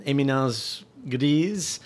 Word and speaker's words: éminence grise: Let's see if we can't work Dick Cheney in this discussion and talk about an éminence grise.